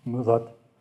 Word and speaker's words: Muzot: I have to say a word about that because this little castle tower that Rilke lived in Muzot, that's how he pronounced it, Muzot, had no central heating.